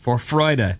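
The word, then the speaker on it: Vorfreude: For Freude. – For Freude. Nice pronunciation. Well done.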